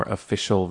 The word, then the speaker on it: official: But in fact, the emphasis should be on the fish. Official, not official. Official.